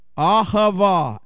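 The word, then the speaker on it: Ahava (אהבה): The Hebrew word for love is, I'm going to spell this for you, Ahava. A-H-A-V-A. A-H-A-V-A. And you're welcome. A-H-A-V-A. That's the Hebrew word for love. And it's Ahava. That's how you say it.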